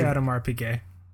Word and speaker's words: Audemars Piguet: I say Audemars Piguet. Yeah. – Audemars Piguet. Okay, cool. Yeah. Jaeger LeCoultre, if you will. Very good. – And JLC. Yeah. – JLC. Audemars Piguet.